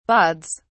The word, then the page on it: bauds: British English